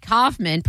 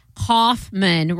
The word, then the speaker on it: Kaufman: Okay, well, it says right here, Andy, pronounced Andy, rhyming with handy, without the H, and Kaufman, pronounced Kaufman, rhyming with cough plus man.